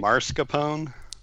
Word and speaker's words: mascarpone: Mascarpone cheesecake.